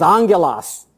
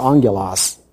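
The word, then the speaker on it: angelos (ἄγγελος): If you pronounce it in Greek, it's angelos. The N and the G are actually in the Greek two G sounds or the gamma. And when the two gammas are put together, it creates this NG sound, and so it's pronounced angelos.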